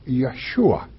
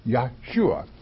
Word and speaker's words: Yeshua: If you Jesus in Hebrew is Yeshua, okay, Yeshua. Let's take the word Julia.